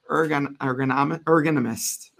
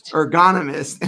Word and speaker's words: ergonomist: Is a certified applied ergonomist. I'm saying it wrong. Help me out. – Ergonomist and received a certification as a remote ergonomics assessment specialist in 2020.